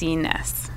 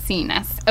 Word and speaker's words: Itsines: It's um, it's Itsines. It's Itsines. Okay, yeah.